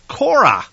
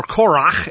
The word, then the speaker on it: Korach: This week we are talking about the parsha called Korah, or Korach is actually the way it's pronounced in the Hebrew.